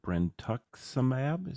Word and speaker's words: brentuximab: Things that came out of that, I found the word brentuximab.